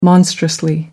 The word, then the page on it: monstrously: American English